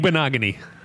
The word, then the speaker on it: Igbinoghene: Igbinoghene. – Igbinoghene gives them that.